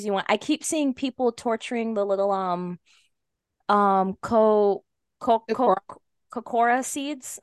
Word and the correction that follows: Korok: Kokora. Am I saying that right? Yes. – Yeah. – I thought it was Korok, but I could. – Korok. There we go. I like Korok better.